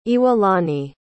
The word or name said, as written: Iwalani